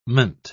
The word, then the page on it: -ment: British English